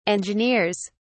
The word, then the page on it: engineers: British English